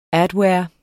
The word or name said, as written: adware